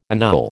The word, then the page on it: annul: American English